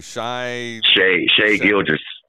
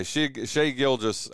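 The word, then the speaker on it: Shai Gilgeous: Shea Gilgeous. Yeah, Shea Gilgeous, Alexander, whatever, dude.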